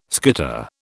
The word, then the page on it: skitter: British English